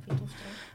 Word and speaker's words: Phytophthora: Ja, Phytophthora.